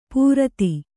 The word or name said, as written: ಪೂರ್ತಿ